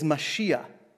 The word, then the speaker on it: Mashiach: The word here for anointed is Mashiach. And if Mashiach sounds familiar, it's how we pronounce it in English, Messiah.